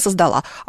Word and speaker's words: создала: В общем, если она, то, как правило, в таких глаголах всегда будет ударение на последний слог. Она создала. А вот если он создал.